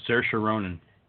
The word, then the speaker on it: Saoirse Ronan: How do you pronounce it? – Oh, Saoirse Ronan.